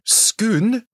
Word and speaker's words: Scone: Scone? – Spelt S-C-O-N-E. So it's spelt scone, but it's pronounced scone by Scottish people.